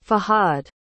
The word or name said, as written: Fahad